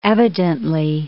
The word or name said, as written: evidently